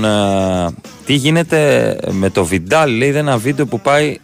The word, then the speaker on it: Βιδάλ: Θα δω αν τραυματίστηκε ο, ο Βιντάλ. Να σε ενημερώσω ή ο Βιδάλ, δεν ξέρω τώρα πώ προφέρεται. Πάτησα στο Google και μου έβγαλε οι 22 ποδοσφαιριστές που έχει τραυματίσει ο, ο Βιδάλ. – Έτσι.